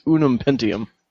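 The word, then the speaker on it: Ununpentium: It's Unum Pentium.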